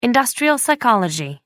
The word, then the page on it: industrial psychology: American English